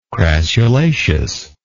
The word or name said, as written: crassulaceous